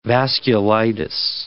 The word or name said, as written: vasculitis